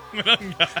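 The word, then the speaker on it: Monangai: Kyle Manunge. He went for That's not how you say it.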